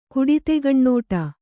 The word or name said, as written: ಕುಡಿತೆಗಣ್ಣೋಟ